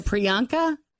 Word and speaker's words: Priyanka: Does anybody know the proper way to say her first name? – I do. If you show it, because I kept hearing Natalie Morales say Priyanka. – Yes. – Is it Priyanka? – Priyanka. – And does she say Chopra or Chopra? – She said Chopra.